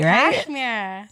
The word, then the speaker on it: Cashmere: I totally have not been pronouncing the H. It's Cashmere. – Yes. – That shit right? – Cashmere.